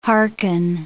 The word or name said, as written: hearken